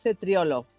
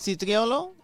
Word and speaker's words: cetriolo: Doncs el cetriolo... – Cetriolo, no? – Sí, se li ve el cetriolo. – Cetriolo, cetriolo. – Cetriolo. – Sí. – La C i la E es pronuncien che.